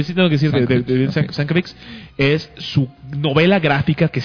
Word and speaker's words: Sienkiewicz: ¿Cómo se pronuncia? – Sienkiewicz. – Uy, ok. – Sí, está cañón. – Es que se escribe Sienkiewicz. Sienkiewicz. – Mucha gente dice Sienkiewicz. – Sienkiewicz. – Es muy difícil de decir.